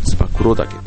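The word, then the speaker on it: 燕岳: つばめ岳と書いてつば九郎岳というねえっ、ー、ところに行ってこようかなと思ってるんですね。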